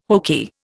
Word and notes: hokey: Indian

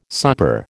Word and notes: supper: American English

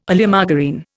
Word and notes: Oleomargarine: Indian

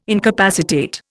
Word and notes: incapacitate: Indian

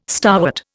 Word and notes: starwort: Indian

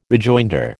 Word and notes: rejoinder: American English

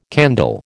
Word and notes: candle: American English

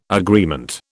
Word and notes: agreement: British English

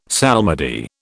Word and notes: psalmody: British English

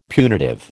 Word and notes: punitive: American English

